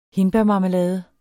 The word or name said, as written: hindbærmarmelade